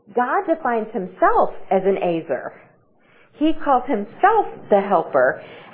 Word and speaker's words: ezer: So the word here, it's a Hebrew word, and it's spelled E-Z-E-R, and it's pronounced Azer. I always would say Ezer, but it's Azer.